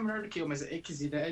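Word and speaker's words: Xillia: é Xilia. Confere isso aí, Desmax. – Eu falava Gília. – Olha aí. É É, tem o som do Z, não é Xilia. É, tô...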